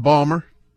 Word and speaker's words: Baltimore: I said Balmer. – Balmer. – Is that wrong, Jay? – In certain parts of the city of Baltimore, that's how you say it, for sure.